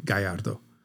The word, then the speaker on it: Gallardo: And a Spaniard would say Gallardo.